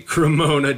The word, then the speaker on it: Cremona: I bet I've said it's not a Serramonna, is it? Well, how do you pronounce it? – It is a Cremona J.